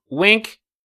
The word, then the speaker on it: Winc: I'm talking about Wink. It's spelled W-I-N-C. Not the way like you do with your eye when you're like flirting with a sexy person, or you're telling like a great joke to somebody secretly across the room.